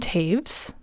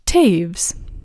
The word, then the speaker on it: Toews: Like I said, my name is Erin Taves. That's spelled T-O-E-W-S, Taves. Kind of sounds like caves.